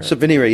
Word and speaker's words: Subvenire: You said were kind enough to send me, and am I is it pronounced just like it looks, Subveneer? – Subveneer, yeah, which okay. is not bad. Some people have one reviewer had said it was bad French. No, it's Latin.